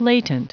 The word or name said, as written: latent